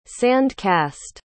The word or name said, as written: sand-cast